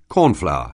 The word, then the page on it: cornflour: anglais